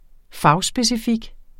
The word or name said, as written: fagspecifik